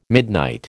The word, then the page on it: midnight: American English